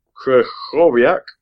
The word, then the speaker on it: Krychowiak: Um, so, and, and with Krychowiak, if I've pronounced that right.